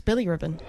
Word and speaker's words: bilirubin: It's bilirubin.